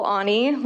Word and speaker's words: Aune: My name is Nicole Aune. Last name is spelled A-U-N-E,